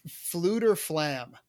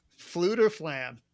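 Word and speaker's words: Fflewddur Fflam: His name is Fluter Flam. I want to spell it for you. It's FFLEWDDUR. FFLAM. Fluter Flam is his name.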